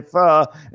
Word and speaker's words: faux: I don't know how many times I have to say faux instead of faux. Faux.